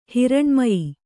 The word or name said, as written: ಹಿರಣ್ಮಯಿ